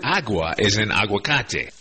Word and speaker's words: aguacate: They're called aguacate trees in Spanish. And by the way, the first syllable in that word aguacate is agua, A-G-U-A, meaning water.